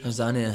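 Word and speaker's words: Arzani: Is it Arzania it's pronounced? – Arzania, huh? – Yeah.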